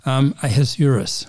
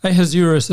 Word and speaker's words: Ahasuerus: Although he's called in the book Ahasuerus, at least that's how I pronounce it. – Yes, I said Ahasuerus, but I don't know either.